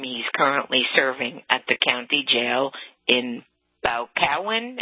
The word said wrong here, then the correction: Boscawen: Am I pronouncing it properly? – Boscawen, yeah. – Boscawen, I beg your pardon.